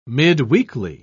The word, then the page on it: midweekly: British English